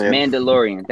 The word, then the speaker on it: Mandalorian: So how do you pronounce it again? Because I don't want to... – Mandalorian. – Thank you.